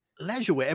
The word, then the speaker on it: Lajoie: It is Lajoie, right? – Correct. Yes. – So that is, it is like good luck, basically, trying to figure out how that's pronounced. But I've always said Nap Lajoie. – Yeah. – But Lajoie, I guess it kind of makes sense, because it's all those vowels, like unless they're diphthonged or something. – Right. – You got to pronounce all the vowels, right? Lajoie.